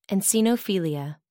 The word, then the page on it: eosinophilia: American English